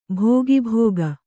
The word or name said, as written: ಭೋಗಾಭೋಗ